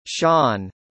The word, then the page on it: sean: American English